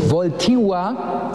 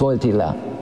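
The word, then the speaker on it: Wojtyła: Aber man dachte, es wären Afrikaner, weil Voltiwa wird der Name korrekt ausgesprochen. Nicht Voltila.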